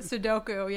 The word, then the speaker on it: Sudoku: And is it I always say it wrong. Sudoku? Is that what? – Sudoku, yeah.